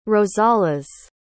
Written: rosales

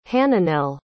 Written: hananel

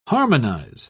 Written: harmonize

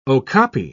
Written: okapi

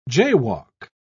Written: jaywalk

